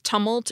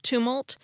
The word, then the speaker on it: tumult: I'm not sure why I mispronounced it as tumult instead of tumult, except that I'm not sure I've ever heard anyone else say the word. But I have heard the adjective form, tumultuous, as in, it's been a tumultuous week. So I was probably modeling the pronunciation a bit after the word I had heard, although I wasn't exactly saying it that way either.